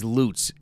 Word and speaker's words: Lutz: It's Lutz, and I was corrected. Don't ever mispronounce that.